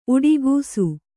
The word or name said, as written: ಉಡುಗಿಸು